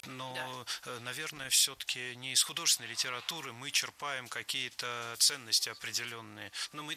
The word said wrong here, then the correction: черпаем: Черпаем, неверно. Правильно, черпаем. Здесь ударение тоже должно падать на корень слова. Черпать, черпаешь, черпает, черпаем.